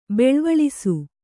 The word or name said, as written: ಬೆಳ್ವಳಿಸು